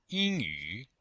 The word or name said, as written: ying1yu3